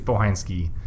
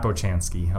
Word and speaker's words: Bohansky: Steve Bohansky, not Bochansky, I'll tell you. – It's spelled like Bochansky, but it's Bohansky. – It's spelled like Bochansky, but it's Polish, so it's Bohansky.